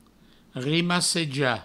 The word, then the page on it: rimassejar: rimasseja; Provençal